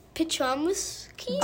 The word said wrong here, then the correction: dichotomous: Dichotomous. – Dichotomous. – Yeah, we struggled with that. – Like a P. – It doesn't look like it sounds.